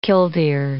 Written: killdeer

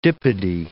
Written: dipody

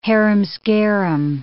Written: harum-scarum

